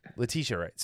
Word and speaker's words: Letitia Wright: I just don't think Letitia, is that how you say it? Letitia Wright? – Letitia Wright.